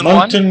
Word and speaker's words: Moncton: Since 2001, Moncton, New Brunswick.